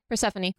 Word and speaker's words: Persephone: Persephone? – Yeah, Persephone.